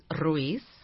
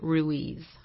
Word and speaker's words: Ruiz: In Spanish, it's Ruiz. – I can't say that. – In English, we pronounce it Ruiz.